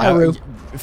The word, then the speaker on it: Hyrule: I was going to say, it's Hyrule. You don't pronounce the L? – It's Hyrule.